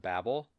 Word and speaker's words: Babel: Yeah, and I just want to say I'm really happy to hear you call it Babel. On the official website, it says Babel, B-A-B-B, or B-A-B-E-L, pronounced, quote, B-A-B-E-L.